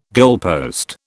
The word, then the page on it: goalpost: British English